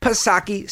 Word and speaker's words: Psaki: So, of course, you've got good old Jen Pacircleback Psaki, silent P, of course.